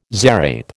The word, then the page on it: zarape: American English